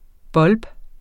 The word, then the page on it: bulb: ˈbʌlb; ˈbulˀb